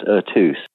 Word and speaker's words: Eux: Well, it's pronounced Ertus. – Ertus, okay. – Ertus, yeah. Ertus is just an irregular French word. It just means all of them in French, and um, there is a similarity in feel between Eine Symphony and Ertus, I think, in my opinion.